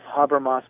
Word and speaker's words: Habermas: Pronounced Habermas, but spelled H-A-B-E-R-M-A-S. H-A-B-E-R-M-A-S.